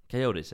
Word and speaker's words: Coyotes: Och eh, en spelare som um, spelar i Arizona Coyotes, eller Coyotes. Coyotes är det säkert. Jag tycker båda uttalen var bra.